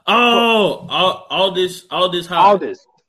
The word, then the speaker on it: Aldis Hodge: Adderis? Oh man, Hodge, you know my man. – Hodge. Aldis Hodge.